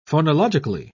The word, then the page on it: phonological: British English